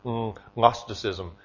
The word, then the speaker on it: Gnosticism: Okay, it's spelled with a G. I don't really have a sinus problem, but it's Gnosticism.